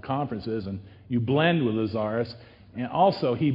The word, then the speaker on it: Lazarus: Now, Lazarus, that's how it's pronounced, Lazarus, because I got familiar with this entity right off the bat when I started researching the New Age.